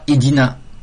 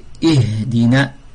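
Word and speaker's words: اهدنا: Le ha, on ne dit pas idina, c'est pas idina, c'est ihdina, ihdina sirat al-mustaqim, ihdina sirat al-mustaqim.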